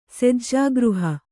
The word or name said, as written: ಸೆಜ್ಜಾಗೃಹ